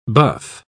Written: buff

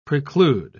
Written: preclude

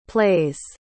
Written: plays

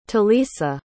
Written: talisa

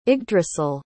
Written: yggdrasil

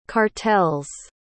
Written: cartels

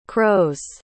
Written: crows

